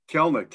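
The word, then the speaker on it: Kelenic: Kelnick.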